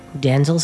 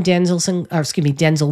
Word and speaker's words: Denzel: Day in, day out, Denzel Sinclair from his self-titled release of 2003.